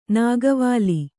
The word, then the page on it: ನಾಗವಲ್ಲಿ: nāgavalli